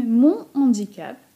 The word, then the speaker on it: mon handicap: Zrobimy więc liaison przy mon i przy cet oraz cet zapiszemy c-e-t. Tę formę stosujemy dla rzeczowników rodzaju męskiego zaczynających się na samogłoskę lub h muet. Z drugiej strony powiemy mon handicap bez liaison oraz ce handicap bez liaison i pisane klasycznie, że tak powiem, CE.